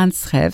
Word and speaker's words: Antsrev: Bon, parlez-nous de la prochaine pièce que nous allons entendre qui a pour titre Antsrev, c'est comme cela que l'on prononce? – Oui, Antsrev, ça veut dire Antsrev, en fait, ça veut dire pluie.